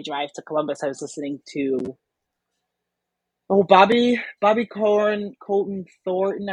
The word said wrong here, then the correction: Conte: I don't know if I said his name right. – I know who you mean. – Yes. – There's Bobby Conte in there. – Bobby Conte Thornton. That's how you say it. Oh, yeah. Bobby Conte Thornton. That's how you say his name.